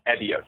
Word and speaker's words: Evio: It's Evios, E-V-I-O.